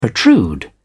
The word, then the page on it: protrude: British English